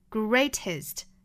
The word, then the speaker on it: greatest: 每步加上了 est 的读法应该是一个 i, 松口的那个 i。短元音大家不要读成了一是 greatest greatest